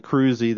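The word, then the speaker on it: Krusee: Mike Cruzy, that's Cruzy spelled with a K, K-R-U-S-E-E.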